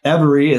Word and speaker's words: Everee: So Everee is spelled E-V-E-R-E-E. So there's a lot of play on words there, but everybody, every day, spelled a little bit different. But the EV is every, like every day.